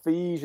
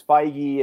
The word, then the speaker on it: Feige: So I'm kind of surprised we didn't get any jokes about how you actually pronounce his last name. – Feige well, it's Feige, it's Feige.